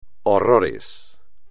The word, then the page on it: horrores: espagnol